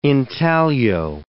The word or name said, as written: intaglio